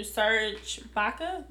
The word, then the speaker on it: Serge Ibaka: And Charlamagne was talking to Serge Ibaka. Am I saying his name right? – Yes, you are.